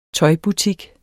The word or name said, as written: tøjbutik